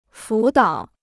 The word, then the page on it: 辅导: fǔ dǎo